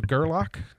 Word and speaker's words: Gerlach: Gerlach.